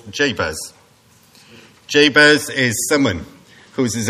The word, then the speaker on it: Jabez: His name is what it says on the tin, Jabez.